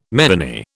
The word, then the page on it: meany: British English